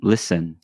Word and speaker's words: listen: Why would you put T on listen? – Yes.